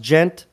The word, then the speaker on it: djent: Yeah, so it's pronounced djent, but it's spelled D-J-E-N-T.